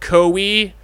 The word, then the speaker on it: Koei: So I'm excited that on the 26th of September, fucking Nintendo, and I believe you pronounce it Koei, Koei. It's Japanese. The uh, people, uh, yeah, yeah, right. – It's Koei.